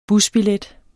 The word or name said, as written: busbillet